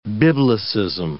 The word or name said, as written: biblicism